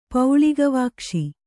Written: ಪೌಳಿಗವಾಕ್ಷಿ

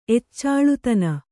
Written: ಎಚ್ಚಾಳುತನ

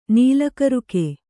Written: ನೀಲಕರುಕೆ

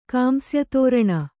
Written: ಕಾಂಸ್ಯತೋರಣ

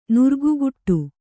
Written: ನಿರಿಗೆಗಟ್ಟು

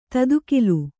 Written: ತದುಕಿಲು

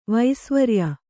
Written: ವಸುವೀರ್ಯ

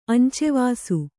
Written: ಅಂಚೆವಾಸು

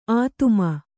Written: ಆದಿಮ

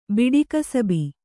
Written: ಬಿಡಿಕಸಬಿ

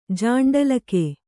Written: ಜಾಂಡಲಕೆ

